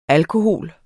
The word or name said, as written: alkohol